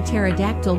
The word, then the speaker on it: pterodactyl: Why can't you hear a pterodactyl going to the bathroom? Because the P is silent.